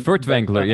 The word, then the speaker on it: Furtwängler: Furtwängler, yeah.